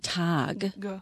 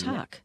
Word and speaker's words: Tag: Zum Beispiel mit Tag. Man sagt nicht Tag, man sagt Tag. Genau. Das ist mit ohne Stimme.